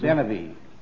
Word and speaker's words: Benavie: Benavie. – Benavie. How do you spell it? – B-E-N-A-V-I-E.